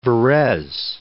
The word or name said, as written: Varese